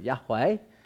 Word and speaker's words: Yahweh: But notice it's the fear of the Lord, capital L-O-R-D, not just the fear of G-O-D, God. You see, who is the Lord? The Lord, next slide, actually is Y-H-W-H. I pronounce it Yahweh, but no one knows how to pronounce it because the Jews, they only wrote it down.